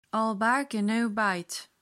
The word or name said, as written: all bark and no bite